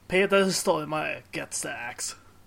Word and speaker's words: Stormare: Stormare. – Peter Stormare. I can't pronounce it completely correct either, because I can't pronounce the Swedish hard R.